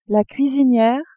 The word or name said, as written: cuisiniere